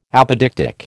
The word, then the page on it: apodictic: American English